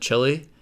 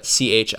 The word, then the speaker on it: Chili: So I don't know for sure why they pronounce it Chili rather than Chile, but I think they are very proud of the fact that they don't live in Chile.